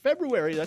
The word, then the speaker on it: February: But February, there's an R in there. So many people neglect that R and make it February, right? – Yeah, a lot of folks do.